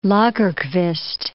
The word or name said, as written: Lagerkvist